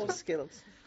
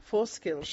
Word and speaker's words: Fur Skills: Es mi profesora de inglés de la Academia Fur Silk. Lo he pronunciado horrible. A ver, ¿cómo se pronuncia? – Fur Skills. – ¿Cómo? – Fur Skills.